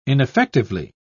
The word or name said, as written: ineffectively